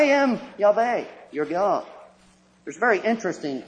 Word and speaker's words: Yahweh: We don't know how it was pronounced. The Hebrews, instead of pronouncing it, substituted a different word, the word Lord. If we attempt to pronounce it, we would read it something like, "I am Yahweh, thy God."